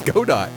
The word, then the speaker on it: Godot: It's Godot.